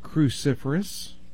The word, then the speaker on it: cruciferous: They're called cruciferous. – Cruciferous. – Well, let me try to spell it. Okay. Do you have a pencil handy? – Yes. – C-R-U-C-I-F-E-R-O-U-S.